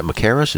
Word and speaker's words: Machaerus: And again, is that Machaerus? Is that the name of the place? – Yeah, I believe that's how you pronounce it.